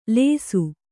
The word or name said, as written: ಲೇಸು